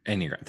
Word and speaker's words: Enneagram: I think what also ties into that is your dyspersonality or your Enneagram. Did I say that right? Because I'm dyslexic. – Enneagram. – Thank you. – Yeah, you were really close.